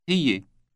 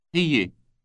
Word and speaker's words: هي: هي. – This word contains an elongated Y sound in the middle. Listen to it again and repeat. – هي. هي.